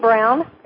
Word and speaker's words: Braun: Kate Brown. – You got it right. – It's because her name looks like Braun when it's spelled, but it's pronounced Brown.